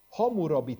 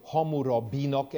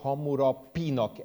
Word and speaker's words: Hammurabi: Az utóbbi időben elég sok kutatást végeztek Hammurabi törvénykönyve, van, amikor ezt B-vel írják át, és van, amikor P-vel írják át. Tehát van, amikor Hammurabinak ejtik, és van, amikor Hammurapinak ejtik. Mind a két átírás helyes.